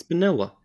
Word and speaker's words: Bonilla: Just say his name. It's Bonilla.